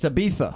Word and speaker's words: Ibiza: This is according to Ibiza. – It's Ibiza. – Oh, he's using the Castilian pronunciation.